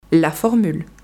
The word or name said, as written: la formule